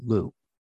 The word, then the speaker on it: Lugh: And so I was working with the Celtic god, and I have no pantheon, but I was working with the Celtic god, Lu. L-U-G-H is how it's spelled.